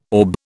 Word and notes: orb: British English

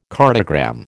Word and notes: cartogram: American English

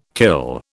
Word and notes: kill: British English

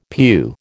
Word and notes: pew: American English

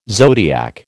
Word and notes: zodiac: American English

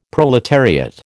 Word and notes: proletariat: American English

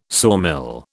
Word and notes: sawmill: British English

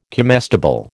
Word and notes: comestible: American English

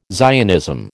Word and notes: Zionism: American English

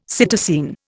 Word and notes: psittacine: Indian

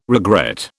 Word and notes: regret: British English

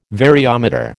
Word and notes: variometer: American English

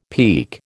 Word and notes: peke: American English

